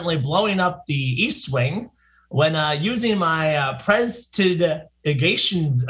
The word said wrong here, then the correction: prestidigitation: attempt to add more prestidigitation. – Alcohol. Prestidigitation, right? – That's close enough.